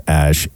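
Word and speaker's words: X Æ: So the name, as you just said, the Grimes and Elon Musk, X-A-E, which is, I believe, pronounced Ash, A-12, but instead of 12 as in 1-2, it is now 12 as in X-I-I, as in the Roman numerals 12.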